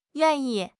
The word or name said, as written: yuan4 yi4